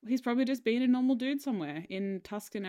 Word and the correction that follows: Tucson: pronounced Tucson.